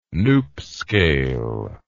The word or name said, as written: Knoop scale